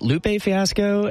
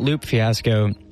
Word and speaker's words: Lupe Fiasco: I've heard it Lupe Fiasco and I've heard it Loop Fiasco. And I looked up to see if there was like interviews on him. Maybe he said his own name. He says both.